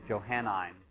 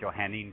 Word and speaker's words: Johannine: And we're going to call that Johannine Christianity. Or some people might pronounce it Johannine Christianity. The same thing.